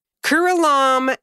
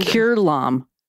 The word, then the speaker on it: Curelom: I'm trying to go off the pronunciation here. Curelom and Cumom. How do you spell this shit? C U R E L O M. That's the first word. And Kurlam not according to this pronunciation.